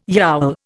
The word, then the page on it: yowl: Indian